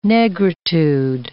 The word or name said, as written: negritude